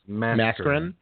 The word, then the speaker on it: Mascherin: It's Mascherin. Mascherin is not signing with them and is going to re-enter the draft, so they'd like to get value back for him.